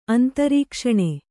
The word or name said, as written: ಅಂತರೀಕ್ಷಣೆ